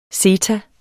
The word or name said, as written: zeta